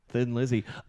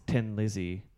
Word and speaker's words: Thin Lizzy: and they added the H. So it would be pronounced in the dialect as Thin Lizzy. – Exactly the same as it's spelled.